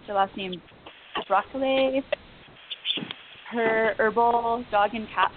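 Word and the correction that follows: de Baïracli Levy: DeBerrickley Levy. – DeBerrickley.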